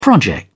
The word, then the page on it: project: British English